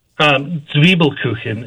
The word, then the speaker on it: Zwiebelkuchen: Zwiebelkuchen is the way that it's pronounced. It translates to essentially onion cake, but Zwiebelkuchen.